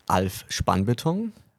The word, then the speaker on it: Alf Spannbeton: Did I pronounce it right? Kinda. – Okay, how I would mean, you say it's, it? It's German. It's Alf Spannbeton.